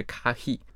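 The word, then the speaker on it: かひ: 对，就是可否这两个中文字在日文里面，它的表达就是是否可以的这个意思的时候，它的读音是 kahi，所以它就很像咖啡的那个音。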